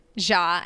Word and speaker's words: Jha: Love Neha, N-E-H-A. Ja, I think it's how you pronounce her last name, J-H-A. So L-O-V-E-N-E-H-A-J-H-A.